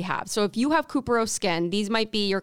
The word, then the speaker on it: couperose: It's spelled C-O-U-P-E-R-O-S-E. – So it's kind of like Cooper. – Yeah, Cuperose Skin.